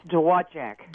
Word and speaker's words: Dowagiac: It's Dowagiac.